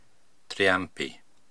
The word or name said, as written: Triampi